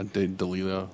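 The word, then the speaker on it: D'Elia: Is it Delia? Is that how you say his name? – Who cares? Yeah, Delia.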